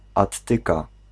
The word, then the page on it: grenier: [ɡʁǝ.nje]; /grə.nje/